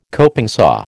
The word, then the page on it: coping saw: American English